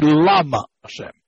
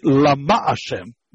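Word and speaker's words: Lama Hashem (למה): it should be Lama Hashem. We don't say that, we say Lama Hashem, meaning the emphasis in the second syllable.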